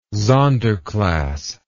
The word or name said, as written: sonder class